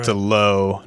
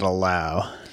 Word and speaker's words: Lowe: So the Rays, speaking of, they have Brandon Lau, Nathaniel Lowe. They have another one, Josh Lowe. Is that the Lowe pronunciation? – He's Nate Lowe's younger brother, so a Lowe, not a Lau.